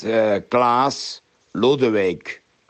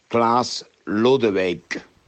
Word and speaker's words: Klaas Lodewijk: It's Klaas Lodewijk. I repeat, Klaas Lodewijk.